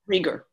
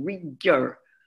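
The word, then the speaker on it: Reger: But is pronounced Rieger, right? – Rieger, right. Because in Polish, it's it's a sound that's kind of in between. Rieger.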